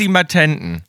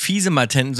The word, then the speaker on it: Fisimatenten: Um ihre Töchter vor frivolen Abenteuern abzuhalten, warnten die Muttis, mach bloß nicht wieder Fissi Fissimatenten. Ach, das heißt gar nicht fiese Matenten, sondern Fissi Matenten, echt, aus dem Französischen.